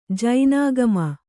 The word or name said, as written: ಜಿನಾಗಮ